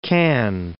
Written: Cannes

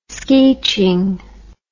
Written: skeeching

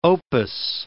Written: opus

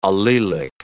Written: allelic

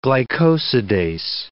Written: glycosidase

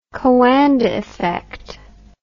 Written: Coanda effect

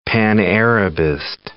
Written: pan-Arabist

